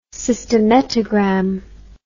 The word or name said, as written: cystometrogram